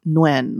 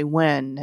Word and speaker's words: Nguyen: It's pronounced Nguyen, like N-W-I-N. – So Nguyen. – You can always say Nguyen, and it would always be like a win-win situation, I suppose.